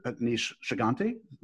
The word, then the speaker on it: Aneesh Chaganty: I don't want to butcher his name, but Aneesh Chaganty. Did I have that close? – Yes.